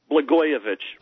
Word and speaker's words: Blagojevich: Blagojevich. I've never heard it pronounced before. – Blagojevich.